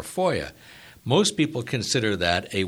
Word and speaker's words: FOIA: Just prior to the break, we were talking about FOIA. That's the pronunciation of the acronym FOIA that stands for the Freedom of Information Act.